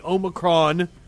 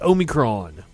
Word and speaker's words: Omicron: Meanwhile, the CDC has issued new guidance in light of the Omicron. I think I'm saying it wrong. I think it's Omicron.